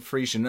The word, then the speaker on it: Frisian: Yeah, I'm talking Frisian.